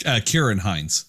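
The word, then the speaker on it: Ciarán Hinds: I also really did enjoy, now I always forget how to say this name, so you'll forgive me, but Sirian Hines? – Kieran Hines. – Kieran Hines. Okay, I always mess it up.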